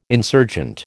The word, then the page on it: insurgent: American English